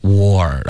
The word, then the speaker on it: war: OK，这个倒 C 搭配到前面的 W 的时候呢，它的发音就是一个窝的音，跟这个时候就可以用中文，就是比如你说猪窝的这个窝， Okay，这个发音就很相似了，结尾加个儿化音 war，然后你就说了一个十分地道的美语、战争的这个字的发音 war，然后注意你的嘴唇会慢慢的分开，导致一个滑动 war，